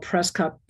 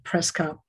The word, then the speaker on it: Przekop: His last name is spelled Preskop, P-R-Z-E-K-O-P, Preskop.